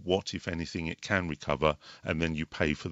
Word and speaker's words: Recuva: So there's one called, there's a free software as well called Recover, but it's spelled R-E-C-U-V-A. And